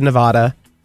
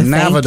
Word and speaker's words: Nevada: If I was saying naturally, I'd say Nevada. With how you said it. I know. Okay. I know. But now since I read this, I've been trying my best to say it correctly.